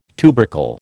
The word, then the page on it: tubercle: American English